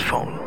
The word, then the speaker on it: Phaune: It's spelled P-H-A-U-N-E. But it's pronounced phone.